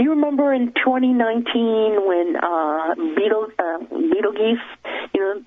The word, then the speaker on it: Betelgeuse: Betelgeuse. That's the actual pronunciation of that Interesting.